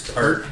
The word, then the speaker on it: Peart: Peart. – It's pronounced Peart, but I say Peart.